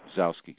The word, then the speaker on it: Gzowski: Peter Gazowski doing interviews and shit like that, you know. – The G is not pronounced. – Gazowski.